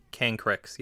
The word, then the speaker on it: Cancrix: Is that how you say it? Cancrix? – Cancrix, yes. There's also a Cancrix in this set,